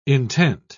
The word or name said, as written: intent